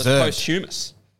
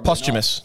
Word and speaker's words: posthumous: That was posthumous, you know? – Yeah. – If that's how you pronounce that. – Posthumous. – Not. Posthumous.